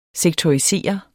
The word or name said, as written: sektorisere